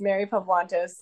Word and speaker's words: Mary Pavlantos: It's Mary Pavlantos.